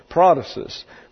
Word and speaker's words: protasis: P-R-O-T-A-S-I-S. Just exactly as it's spelled in the English with the accent on the first syllable. Prothesis, which means literally that which is put forward.